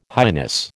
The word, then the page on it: highness: American English